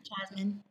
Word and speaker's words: Jasmine: Jasmine.